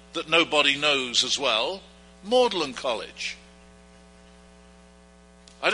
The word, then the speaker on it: Magdalen: It's pronounced in Oxford as Maudlin College, but it's really spelled Magdalen.